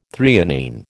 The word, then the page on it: threonine: American English